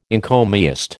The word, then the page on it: encomiast: American English